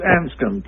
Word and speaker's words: Anscombe: Is that, did I pronounce it right, Tony? – Well, it's Anscombe, but Anscombe. I think it's good. Okay, Anscombe. Okay, so there's a lot of silent letters in there.